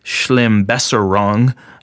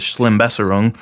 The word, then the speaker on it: Schlimmbesserung: One of the other things that I really like is this German word, Schlimmbesserung. I don't know actually how to pronounce this. Schlimmbesserung, which means negative improvement.